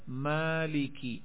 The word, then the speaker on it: مالکی: اب لفظ ہے مالکی, تلفظ ایسے کیا ہے مالکی, میم کے بعد مد کر دی,